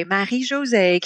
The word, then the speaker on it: Marie-José: I could not spit out those four syllables again. – Yeah. – And so that's when I was like, okay, Marie-José can be complex for people.